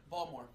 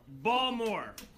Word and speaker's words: Balmer: Baltimore. Pronounce that word. – Balmer. – Balmer. – Balmer. – B-A-L-M-E-R. Yep. – Okay. Gary, pronounce that word. – Picture. – Picture. P-I-X.